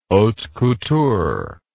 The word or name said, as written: haute couture